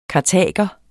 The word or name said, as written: kartaginienser